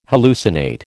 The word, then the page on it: hallucinate: American English